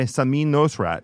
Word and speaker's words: Samin Nosrat: By Samin Nosrat.